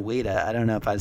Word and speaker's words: Ouida: O U I D A.